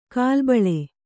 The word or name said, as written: ಗಲಿಬಿಲಿ